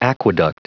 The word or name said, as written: aqueduct